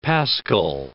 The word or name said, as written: paschal